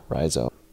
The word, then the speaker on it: Rhizome: So I don't want to get into all the nuance of the business side of things, but there's a Rhizome comedy, which is the same guy who was producing the Helium records. – Did you say Rhizome? – Rhizome. R-H-I-Z-O-M-E. – M-E? What? That's Rhizome.